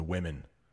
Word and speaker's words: women: Let's talk about the women. Got one woman. Two women. It's kind of the same ending, right? Woman. Women. Change that first syllable a little bit.